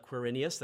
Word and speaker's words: Quirinius: The known world at the time was undergoing a census, and well done on the pronunciation, Caitlin, of Quirinius.